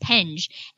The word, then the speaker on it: Penge: Tell me how to pronounce the name of the app. – Yeah, so I call it Penge,